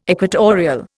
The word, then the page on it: equatorial: Indian